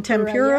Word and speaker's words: tempura: Is that how you say it? Tempura yeah.